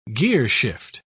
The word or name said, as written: gearshift